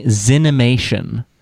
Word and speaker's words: Zenimation: Zenimation. Emphasis on the Zen, Z-E-N.